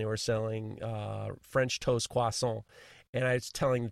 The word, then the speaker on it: croissant: So we're sitting around and she says, it's croissant, no T, croissant, croissant.